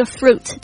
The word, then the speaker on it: fruit: 水果蔬菜 fruit and vegetable， fruit and vegetable，这个 fruit 特别注意一下它的发音，这个 r 的声音跟那个 f 的声音不太好发， fruit fruit，记得舌头要稍微微微卷。